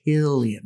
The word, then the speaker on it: Cillian: Is it Killian or Cillian? – It's Killian Murphy. There's no soft C sounds in Gaelic. His name is Killian.